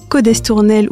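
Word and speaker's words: Cos d'Estournel: Combien de fois avons-nous entendu de tétinger prononcer « tétinger » au lieu de tétinger, codestournel ou collabori sans marquer le S, prononcer le X et le R de Jerez au lieu de la prononciation à l'espagnol, etc.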